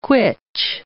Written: quitch

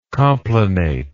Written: complanate

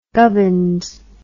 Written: gubbins

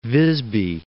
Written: Visby

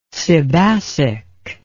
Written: sebacic

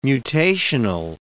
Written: mutational

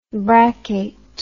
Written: braccate

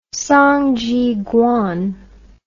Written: Song Zhiguang

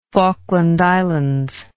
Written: falkland islands

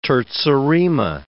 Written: terza rima

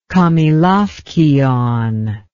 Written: kamelaukion

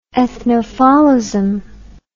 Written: ethnophaulism

